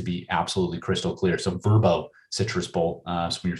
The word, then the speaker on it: Vrbo: The Verbo Citrus Bowl, no longer pronounced VRBO. Their people got in touch with me over the previous years and wanted the LA faithful to know it's Verbo.